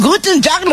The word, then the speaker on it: Guten Tag: Guten Tag, lo dije bien. – Hola. Soy Shona de Alemania y el saludo está muy bien pronunciado.